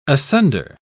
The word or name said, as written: asunder